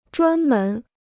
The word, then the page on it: 专门: zhuān mén